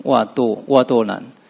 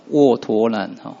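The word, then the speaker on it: 嗢陀南: 那现代中文是第一个字是一个口在一个这是袜子的袜，那第二个是堕了，袜堕难。那我查一下汉语字典，它是有另外一个发音叫握陀难，握就是握手的握，还有陀螺的陀，我觉得应该用汉语字典的发音，因为我刚刚讲的那个握舵是现代中文的发音，就是教育部国语字典的音啊。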